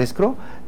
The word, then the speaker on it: escrow: se escribe E-S-C-R-O-W, escrow. Escrow.